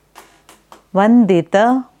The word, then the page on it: Vandita: Sanskrit